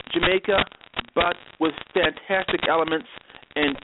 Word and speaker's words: Xamaica: You can pronounce it any way you want. But, you know, I often pronounce it Jamaica. Some kids pronounce it Xmaica. People, I think it's up to the kids to decide how to pronounce it.